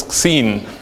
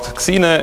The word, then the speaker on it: Xine: Okay, now how do you pronounce it? It's Xeen. Xeen, okay? So nobody will ever say it again, it's Xeen. It's not Xine, it's not Shine, it's Xeen.